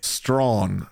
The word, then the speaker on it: Strachan: Strawn. His last name is spelled STRACHAN, and production taught me he pronounces his last name Strawn, so TK Strawn, cool.